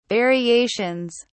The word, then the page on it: variations: British English